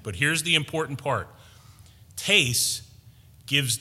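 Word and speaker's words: tes (τῆς): But when you break down that word, tes, T-E-S, long E, tes, where we see of or to, this is tough stuff.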